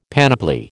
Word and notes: panoply: American English

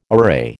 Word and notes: array: American English

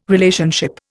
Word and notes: Relationship: Indian

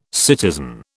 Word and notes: citizen: British English

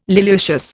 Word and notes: liliaceous: Indian

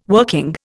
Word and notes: working: Indian

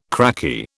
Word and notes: cracky: British English

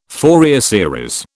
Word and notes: Fourier series: British English

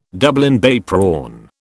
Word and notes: Dublin Bay prawn: British English

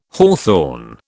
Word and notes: hawthorn: British English

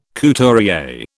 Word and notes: couturier: British English

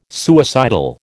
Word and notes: suicidal: American English

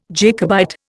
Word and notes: Jacobite: Indian

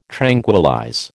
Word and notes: tranquillize: American English

